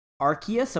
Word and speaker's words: Arceus: as well as the Legends Arceus.